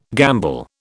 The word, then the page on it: gamble: British English